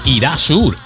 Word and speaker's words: Erasure: El temazo de esta formación se llamó Irasur, Irasur o Erasure, que es como se escribe.